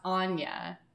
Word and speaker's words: Aine: Is like, oh yeah, it's spelled A-I-N-E, but it's pronounced Anya. Mm. You're like, I'm sorry, what? Or it's spelled S-I-D-H-E, but it's pronounced she.